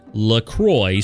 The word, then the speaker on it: LaCroix: Officially, it's pronounced, as I said, LaCroix, so it rhymes with enjoy.